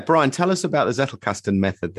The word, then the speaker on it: Zettelkasten: So Zettelkasten, is that the right pronunciation for it? Zettelkasten. – As far as I know, it is.